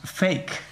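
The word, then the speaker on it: PHEIC: the acronym is pronounced FAKE.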